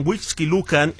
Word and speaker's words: Huixquilucan: Una explosión por acumulación de gas en la parte alta de una torre de departamentos allá en Huixquilucan, ese sí lo dije bien, dejó como saldo, lamentablemente, 3 personas lesionadas y algunos departamentos dañados.